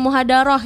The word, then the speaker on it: muhadarah: Dan ini menjadi juara muhadarah. Ini benar gak bacanya muhadarah ya? – Iya, benar gak? – Benar ya bacanya ya?